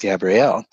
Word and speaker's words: Gabrielle: So I, I, it's it's spelled G A B R I E L, and so for me, she came forth and said, "I'm it's Gabrielle," and so here, this is all to say that there is no sex in in the hmm.